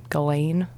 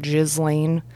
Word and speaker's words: Ghislaine: Ghislaine, not Ghislaine. I'm still going to call her Maxwell.